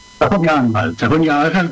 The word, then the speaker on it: Sajonia-Anhalt: Estamos hablando del estado de Sajonia-Anhalt, ¿así se pronuncia? – Sajonia-Anhalt, que sí, que tiene frontera con Polonia, está bien en el este de Alemania.